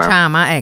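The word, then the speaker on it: chama: Allora, cosa vuol dire Sciama in swahili? A parte che non so se l'ho detto giusto. – Ecco, credo che si pronunci Sciama. – Sciama, ecco, allora correggiamo subito.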